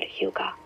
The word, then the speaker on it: hygge: This Danish concept called hygge, yeah, that's how you say it.